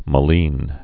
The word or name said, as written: maline